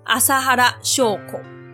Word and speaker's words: 麻原彰晃: 这个胖阿伯就是真理教的教主，他叫做麻原彰晃阿萨哈拉秀口。这个名字不是他的本名，他是后来自己改的。麻原的读音呢，跟阿修罗阿修拉很接近，而彰晃的读音则是跟释迦牟尼佛的释迦夏卡很接近，因此而来的。